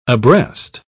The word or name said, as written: abreast